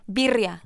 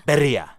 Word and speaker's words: birria: I want to say it right. I'm so bad with birria. – Birria. – Birria. – Birria. – Birria. – Yes.